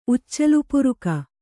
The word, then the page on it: ಉಚ್ಚಲುಪುರುಕಿ: uccalupuruki